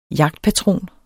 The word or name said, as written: jagtpatron